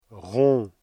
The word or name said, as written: rond